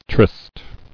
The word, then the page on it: Trysting: Tryst"ing